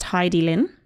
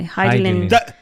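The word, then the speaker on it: Hidilyn: For a start, I think her name is actually pronounced Hidilin. – Oh, Hidilin. – I believe so. Hidilin.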